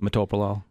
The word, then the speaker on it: metoprolol: The other thing is oftentimes people take metoprolol in the evening because they take it with their cholesterol medication. So That's what I do. I'm on metoprolol. How do you say it? – Metoprolol.